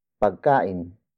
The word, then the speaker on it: pagkain: It is the focus marker, indicating the central figure of this sentence is with Chris, which is pagkain. Pagkain. Pagkain. Pagkain is spelled as P-A-G-K-A-I-N.